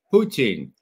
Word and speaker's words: Putin: The first is how do you pronounce Putin in Russian? Everybody assumes it's Putin, and that's why Ray McGovern says it that way. Is that correct, Ray? Well, so I say Lenin or Stalin, Putin.